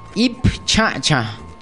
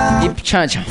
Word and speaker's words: ip chacha: Entonces, nosotros en Nasayue al carbón le decimos ip chacha, chacha. Solamente carbón es chacha. Para decir el carbón, entonces nosotros decimos ip chacha. Ip chacha.